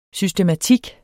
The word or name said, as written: SYSTEMATIKKEN